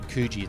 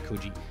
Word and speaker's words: Coogee: You said Coogee, it's actually Coogee.